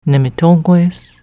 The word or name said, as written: Nemitogwes